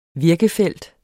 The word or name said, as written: virkefelt